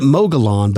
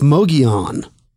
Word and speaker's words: Mogollon: We were pronounce it Mogollon, but yeah. I believe it's Mogollon after listening to Taylor. So I never thought of that two L's. It's kind of like pollo, not polo for chicken.